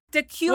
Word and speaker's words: Dacula: The Kula. – Wrong. Dacula. – What is it? – Dacula.